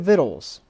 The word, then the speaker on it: victuals: And for then had we plenty of victuals, and that is the proper pronunciation of that word, victuals. It's not victuals, it's victuals. For then had we plenty of victuals, which is food, and were well and saw no evil.